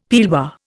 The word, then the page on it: pearlware: Indian